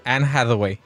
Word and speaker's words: Anne Hathaway: Quien supe que estaba en esta película era Anne Hathaway. – Que se le instruya, dice. – ¿Cómo se escribe? ¿Cómo se pronuncia? – Anne Hathaway. – ¿Hi? ¿How? – Anne Hathaway. – Como hack dog. Anne Hathaway.